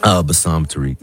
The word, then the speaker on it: Bassam Tariq: Bassam Tariq.